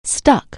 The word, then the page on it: stick: anglais